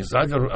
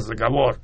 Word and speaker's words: заговор: Но дело все в том, что с моей, опять же, точки зрения, русалки скорее даже не заговорами, я говорю заговорами, Михаил Иванович Царев учил, говорит, не заговор, а заговор.